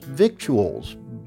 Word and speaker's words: victuals: Now, those eight letters, read literally, suggest the word would be pronounced victuals, but it is not. So let's all say it correctly, shall we? Victuals.